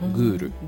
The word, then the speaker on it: ghoul: あるのをちょっとすいません僕も見落としてまして、えっ、とねグールっていう単語があるんですよグールはいスペルはえっ、ー、とね、これは、えー、 GHOUL かな。 – GHOUL。 – はい、グール。